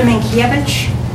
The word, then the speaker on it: Minkiewicz: Patricia Minkiewicz, M-I-N-K-I-E-W-I-C-Z.